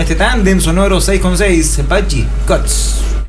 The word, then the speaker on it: Guts: O Guts sería la pronunciación, sí.